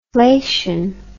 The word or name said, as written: flation